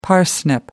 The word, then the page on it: parsnip: American English